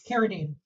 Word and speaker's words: Carradine: Carradine.